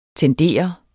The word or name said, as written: tender